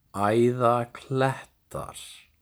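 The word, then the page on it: Æðaklettar: Icelandic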